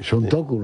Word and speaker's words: Suntoku: ¿ha pronunciado bien Suntoku en japonés o cómo es? – Los japoneses... – La fonética es muy parecida. – Sí, Suntoku, lo ha dicho bien, entonces.